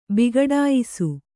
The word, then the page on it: ಬಿಘಡಾಯಿಸು: bighaḍāyisu